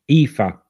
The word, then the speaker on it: Aoife: How would you pronounce her real name? Isn't it A-O-I-F-E? – Aoife. – Aoife. Yeah, I would have never said Aoife.